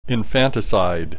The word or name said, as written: infanticide